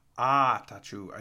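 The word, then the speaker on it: Attaochu: Atachu, I think is how you say it.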